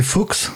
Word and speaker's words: Foax: Euh, et Anto, donc c'est Fox, c'est ça C'est bon, ça. je me suis pas planté sur la prononciation, parfait.